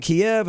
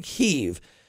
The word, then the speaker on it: Kyiv: Why some people call it Kiev and why do some people call it Kyiv? Kiev is the Russian pronunciation. And Kyiv is the Ukrainian.